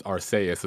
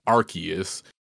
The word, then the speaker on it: Arceus: Well, for one, it's not pronounced Arceus, it's pronounced Arceus.